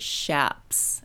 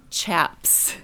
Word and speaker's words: chaps: When I read this aloud at a workshop, the leather things that cowboys and motorcycle guys wear over their pants, I had pronounced it shaps and not chaps. But having looked in Webster's Dictionary, both pronunciations are okay. And I grew up with it being shaps, you know. But I do admit chaps sounds a little tougher.